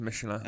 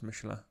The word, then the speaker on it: Michelin: It's pronounced Michelin. – Okay. – I think it's pronounced Michelin.